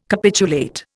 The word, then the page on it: capitulate: Indian